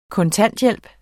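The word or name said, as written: kontanthjælp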